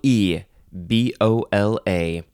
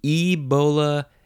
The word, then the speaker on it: Ebola: The first word in this episode is Ebola, with a capital E, B-O-L-A. You can say Ebola, Ebola, Ebola, all those vowel pronunciations.